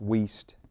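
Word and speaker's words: Wuest: If you're looking for that online, it's W-U-E-S-T, pronounced Wiest, spelt like, don't ask me how, Woost or something anyway.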